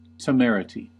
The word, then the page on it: temerity: American English